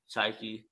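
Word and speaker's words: Saiki: Cause I used to watch Dashiell's Life of Sasuke. I think I said that wrong. – Psyche.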